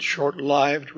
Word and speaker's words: short-lived: By the way, you may notice I pronounce it short-lived rather than lived. I think that should be correct because lived here in this sense derives from the word life with a long I.